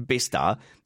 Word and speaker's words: Bicester: And like, God, you can't possibly know if you don't know that it's pronounced Bicester.